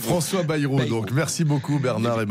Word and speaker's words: Bayrou: Répétez après moi, Bernard, on dit François Bayrou. Bayrou.